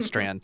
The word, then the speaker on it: Strand: I have like I'm kind of like I have an audible Owen Strand tweet I've stumbled upon. Is that how you say his last name? – Owen Strand. It's pronounced Stran.